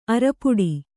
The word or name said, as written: ಆರ್ಪೊಡೆ